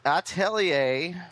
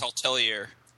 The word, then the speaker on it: Atelier: Atelier, and I'm not going to call it anything else, and I refuse to change the pronunciation.